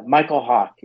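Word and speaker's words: Mykel Hawke: But it's Michael Hawk, and it's spelled MYKELHAWKE.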